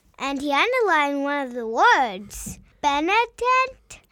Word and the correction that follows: Benedicat: How do you say that again? – Benedicat.